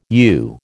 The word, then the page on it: u: American English